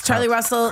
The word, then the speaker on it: Carlee Russell: And we are wrapped up in this Charlie Russell case. – Carly Russell.